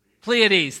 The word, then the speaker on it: Pleiades: Pleiades, thank you.